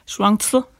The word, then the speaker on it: Shuangzi: Men forfatteren, den taiwanesiske Yang Shuangxi, sagde du det? – Shuangzi, vil jeg udtale det på kinesisk.